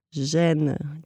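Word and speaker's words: Gênes: Gene, G-E-N-E-S.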